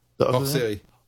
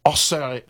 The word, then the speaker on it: Hors Série: Please pronounce the second part. – Hors Série. – Hors? – Hors Série. – Hors Série. Sorry, like yeah, special series.